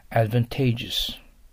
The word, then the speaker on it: advantageous: これはアクセントに気をつけましょう。一緒に発音してください。 advantageous。 advantageous です。